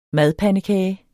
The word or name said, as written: MADPANDEKAGER